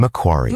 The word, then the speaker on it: Macquarie: Am I pronouncing Macquarie? – Macquarie. Yes. Oh, my goodness.